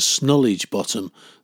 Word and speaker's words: Snodhurst Bottom: Twice around the school field, down the public footpath to Snodhurst Bottom, often pronounced locally as Snollage Bottom, though I've no idea why.